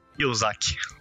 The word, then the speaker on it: Kiyosaki: É, como é que se pronuncia isso? – O quê? É Kirozaki? – Kiyosaki. – Kiyosaki.